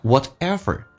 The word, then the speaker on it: whatever: Or whatever kind of person you are，来看一下 whatever，中间那个 T 可以发成 D 这个发音，叫 whatever， whatever kind of Kind 跟 of 在口语化当中直接可以说成 kind，就好像 sort 跟 of 直接说成 sort。